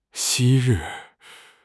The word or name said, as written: 昔日